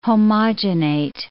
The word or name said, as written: homogenate